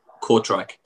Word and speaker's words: Kortrijk: So you're in Kortrijk, is that correct? Did I pronounce that right? – Yeah, Kortrijk.